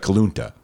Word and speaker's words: Kuluntu: And in Dallas, you have, and I'm probably going to butcher this name, Kalunta Bakery. K-U-L-U-N-T-U. Looks like Kalunta. – Yeah, sounds fine to me.